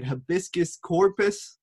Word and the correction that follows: habeas corpus: Do you, is that how you say it? Habeas corpus. Habeas corpus.